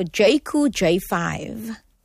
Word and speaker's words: Jayco: Talking about, I mean, I hope I pronounced it correctly also, Jayco J5. – That is spot on.